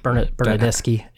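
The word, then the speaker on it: Bernardeschi: Bernadeschi, if I said that right, correct. And then Insigne, it also was a recent. – Bernadeschi and Insigne. – Bernadeschi, Insigne, thank you.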